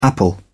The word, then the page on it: apple: British English